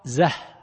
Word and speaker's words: زاي: يقول: ولهذا سأل الخليل، الخليل بن أحمد، سأل أصحابه: كيف تنطقون بالزاي من زيد، لفظة زيد؟ فقالوا زاي، فقال: نطقتم بالاسم زاي. الحرف زه، الحرف إذا نطقت به بالاسم قلت زاي، فذكرت 3 أحرف في الواقع اسما له، لكن إذا أردت أن تنطق بالحرف نفسه تقول زه، حرف الزاي. إذا أردت أن تنطق بالحرف المبنى، حرف التهجي، تقول زه. واضح؟